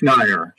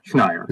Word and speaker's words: Schneier: Schneier? – It's Schneier. Schneier. It rhymes with liar, flyer. – With an N. Well, it's Schneider without the D.